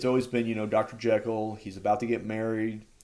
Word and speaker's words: Jekyll: jekyll has been or sorry they actually call him Jekyll, and apparently uh, that's kind of how it's supposed to be pronounced, but it just got stuck Jekyll. So everywhere else is always called it Jekyll. But apparently, like the dude, uh, Robert Louis Stevenson, uh, when he wrote it, the dialect and the way you pronounce things in that town where he wrote it is because it's Jekyll. But yeah, it's Jekyll now.